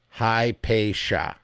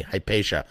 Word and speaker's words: Hypatia: Hypatia. Thank you for phonetically breaking that down for me. Hypatia.